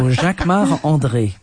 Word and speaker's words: Jacquemart-André: You say it like André. – A Frenchman. Jacquemart-André. – Oh, because I'm French. So Jacquemart-André.